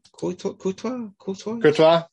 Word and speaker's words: Courtois: Courtois.